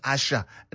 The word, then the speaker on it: Asa: Again, Asha, written A-S-A, but pronounced Asha, not Asa, as some people would think. Because as I said, the S in Yoruba is pronounced as sh. So Asha.